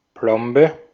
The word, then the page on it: filling: /ˈfɪ.lɪŋ/